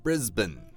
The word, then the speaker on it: Brisbane: 它虽然是 B-A-N-E 结尾啊、right. 就好像班的这个发音嘛布里斯班但是在英文里面 Brisbane. – Right, you guys have probably noticed by now a lot of English words that end with A something E. It's not, we don't say、eight or ain or、mm. age, it's graduate、mm. or Brisbane. Appropriate. – 就比较像那个 B-E-N, 对吧 Ben,、mm-hmm.